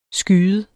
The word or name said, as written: skyet